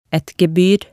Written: et gebyr

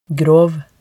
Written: grov

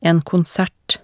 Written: en konsert

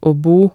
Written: aa bo

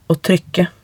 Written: å trykke

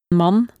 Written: man

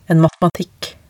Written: en matematikk

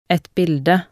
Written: et bilde

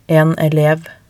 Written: en elev

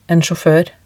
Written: en sjaafoer

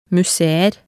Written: museer

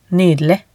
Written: nydelig

